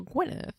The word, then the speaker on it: Gwynedd: On the when the king died in 1169, the brothers fought amongst themselves for the right to rule Gwynedd. – It's spelled like Gwened, but when I looked it up, it was like Gwyneth. Wanna?